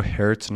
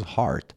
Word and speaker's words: heart: یه نکته، این رو هرت نخونید، H-E-A-R-T، یه common error هست که خیلی از زبان آموزا میگن هرت، تلفظ هارت، توی قلب تهران واقع شده.